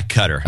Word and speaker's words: Qatar: Oh, you're supposed to say Qatar. – Yeah, Qatar. That's what they pronounce it, by the way.